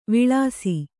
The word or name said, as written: ವಿಳಾಸಿ